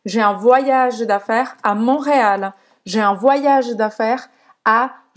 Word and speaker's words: Montréal: Montréal, em francês a gente fala Montréal. O T não se pronuncia.